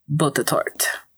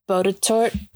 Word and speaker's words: Botetourt: Botetourt. – Do it now. – Botetourt. Botetourt. Pronunciation. Botetourt? – Ooh, what? – Really? – That is not what I thought it was.